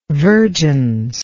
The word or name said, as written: vergence